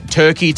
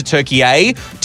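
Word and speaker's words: Türkiye: No, so they changed the pronunciation recently from Turkey to Turkey A. Turkey is the Western sort of pronunciation of it all, but Turkey A is the traditional way to say it, and it's been officially recognised by the United Nations since about 2022, I believe.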